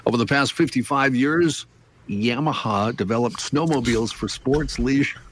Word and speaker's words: Yamaha: Yamaha. Did I say that right? It's Yamaha. Yamaha.